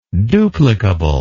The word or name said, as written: duplicatability